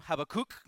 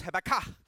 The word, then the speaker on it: Habakkuk: Nobody knows how to pronounce his name. I grew up pronouncing his name Habakkuk. You too? Oh my goodness. Habakkuk. And then I went to school and they told me it was Habakkuk. So there's one part we agree, which is the Habba.